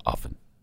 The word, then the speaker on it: often: You don't pronounce the T in often, by the way, if I could just... Okay. – Smiling. – No, it's often.